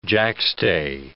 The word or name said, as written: jackstay